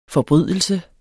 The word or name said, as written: forbrydelse